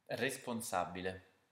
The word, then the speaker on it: responsabile: Next, words that end in able are pronounced as abile. So how would you pronounce the word responsible in Italian? – Responsabile.